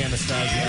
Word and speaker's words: Anastasio: In our studio, we have, ladies and gentlemen, Mr. Trey Anastasio here.